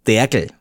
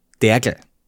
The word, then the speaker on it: Dergl: Sirup oder Verdünnungssäfte sind, und das muss ich österreichisch aussprechen, meine Familie nennt das Derkel. – Derkel? – Derkel. Also D-E-R-G-L, Derkel.